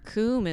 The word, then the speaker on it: Coom: Or they're like Coomb? And I'm like, there's no B. Like, where did you get a B? – That's like Coombs.